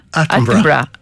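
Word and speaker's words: Attenborough: Από τον Ατένμπορο, ένα άνθρωπο για τον Ατένμπορο, ή πόσο το σωστά η προφορά. – Άτενμπρα.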